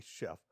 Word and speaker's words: Schrumpf: Um, you got to help me with this pronunciation. Paul J. Schrumpf. – Chef. – Yeah. Paul J. Chef.